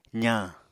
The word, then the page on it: zañ: apatani